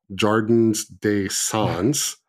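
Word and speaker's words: Jardin des Sons: So you might have seen that Keen Association has released their long-awaited Jardin d'Anson Model 287E, or Ooh. For the non-French speakers, Jardins des Sons.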